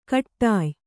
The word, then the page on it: ಕಟ್ಟಾಯ್: kaṭṭāy